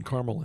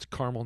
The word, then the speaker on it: Carmel: Northwest side of Indy, right in Carmel, Indiana. And in Indiana, it's Carmel, not Carmel, like by the sea out in San Francisco. That's just how we pronounce it.